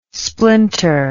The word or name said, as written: splinter